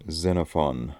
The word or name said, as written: Xenophon